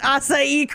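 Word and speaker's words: açaí: I'm amazed that you pronounced acai correctly.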